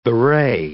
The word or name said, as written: bewray